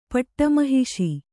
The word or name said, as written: ಪಟ್ಟಮಹಿಷಿ